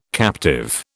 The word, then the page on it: captive: British English